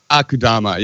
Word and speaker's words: Akudama: Akudama, yeah.